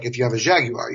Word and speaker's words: Jaguar: Jaguar. Three syllables for those of you that call it a Jaguar.